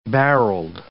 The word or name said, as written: barreled